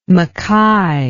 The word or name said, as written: mackay